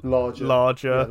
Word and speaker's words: Lage: I saw another little Fabrizio tweet, which was that Bruno, I don't know how to say his name, but like Lage or Larger. – There we go.